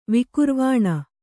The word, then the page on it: ವಿಕುರ್ವಾಣ: kannada